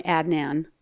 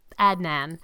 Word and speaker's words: Adnan: It's such a tell-all, and it reveals a lot about your upbringing and your relationship with your lover, Adnan. Am I pronouncing that correctly? – I always just called him Adnan. – Adnan.